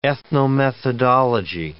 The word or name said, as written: ethnomethodology